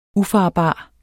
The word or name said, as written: ufarbar